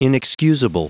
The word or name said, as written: inexcusable